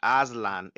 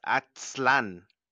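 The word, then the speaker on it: Aztlán: Az, it's spelled Atzln, and it's not Aslan, it's Atzlan.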